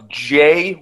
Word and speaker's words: Dieng: So that's not how you pronounce Gorgui Dieng. – I believe it is Gorgui Dieng. DJ Dieng. – It's like I looked it up. It's JYENG.